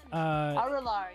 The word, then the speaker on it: Arular: I know that her previous album, Arular, am I saying that right? Arular? – I think so. Arular, yeah. – Yeah, was.